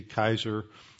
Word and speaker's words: Caesar: Actually, the Caesar was not pronounced with a soft C. It was pronounced with a hard C, Kaiser.